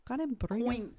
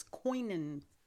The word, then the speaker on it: Conimbriga: Conimbriga. – I think it's Coinin. – There's no I in there. C-O-N.